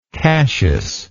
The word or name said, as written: cassius